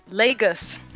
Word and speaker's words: Lagos: Lagos. – Lagos. – It's like lay down in Jaws with a G. – Okay. – Lagos. – Lagos. – Lagos. Yeah. – That's cool. I like playing with Lagos.